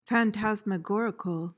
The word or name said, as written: phantasmagorical